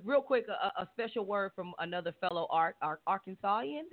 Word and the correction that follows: Arkansan: Did I say that right? Did it? Mm-hmm. Arkansan? Is it? Okay. Whatever. – Okay. Arkansan? Yes.